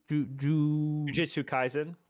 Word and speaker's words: Jujutsu Kaisen: Jujutsu Kaisen.